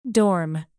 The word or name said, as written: dorm